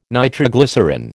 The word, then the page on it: nitroglycerine: American English